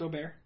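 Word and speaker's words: O'Barr: Is it O'Bear or O'Bar? – It's O'Bear. – O'Bear? – As I understand it, yeah. – Okay. – It's O apostrophe B-A-R-R, but it's pronounced O'Bear.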